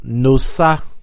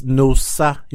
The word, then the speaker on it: Nosa: No, my name is not actually Nosa. Nosa. Wait, it's Nosa. – Okay, well... – So that's the real pronunciation. – Where does the accent that's Nosa. You know how O is different from A? – Accent, yeah, and their phonetic.